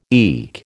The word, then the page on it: eke: American English